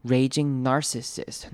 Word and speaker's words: narcissist: Raging 这个字要注意的是长母音 A 的念法， Raging narcissist。 Narcissist 可能是这次比较难念的字，注意到它的两个 I 到念成短母音 I 的声音，开头的 AR 念成 R。 Narcissist，注意重音在第一个音节 nar 上面， like a raging narcissist。